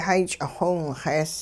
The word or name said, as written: hay ch'ixiwinxees